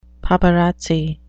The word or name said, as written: paparazzi